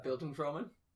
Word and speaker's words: Bildungsroman: Bildungsroman? – Yes, thank you.